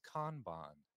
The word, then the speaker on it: Kanban: Pronounced Kanban. I knew you were going to mispronounce it.